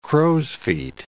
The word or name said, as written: crow's-feet